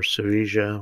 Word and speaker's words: Sevilla: It was the Hispania Sevilla mission, or Sevilla would be better said, Mission, southern Spain, including Las Islas Canarias. My Espanol is terrible. Andalusia, essentially. And yes, when I pronounce the C with a TH, that's correct.